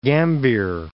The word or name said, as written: Gambier Islands